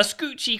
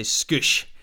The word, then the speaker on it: scoosh: A scoochy question for you. Did I say it right? Scoochy? – I'd say scoosh.